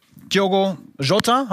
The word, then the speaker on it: Diogo Jota: Diogo Jota, habe ich ihn richtig ausgesprochen? Perfekt. Ja? Du bist Portugiese.